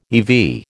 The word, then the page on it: eV: American English